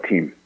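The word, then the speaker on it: TAM: How do you pronounce it? T A M, I think, was how you pronounce it. – We call it team.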